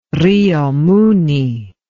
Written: Rio Muni

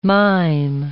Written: mime

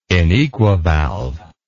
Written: inequivalve

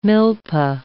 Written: milpa

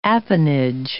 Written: appanage

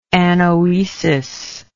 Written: anoesis

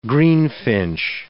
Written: greenfinch

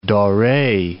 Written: Dore

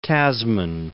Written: Tasman

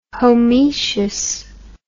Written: homoecious

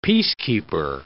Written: peacekeeper

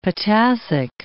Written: potassic

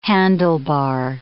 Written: handlebar

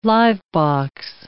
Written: live-box

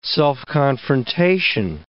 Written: self-confrontation

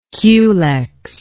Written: culex

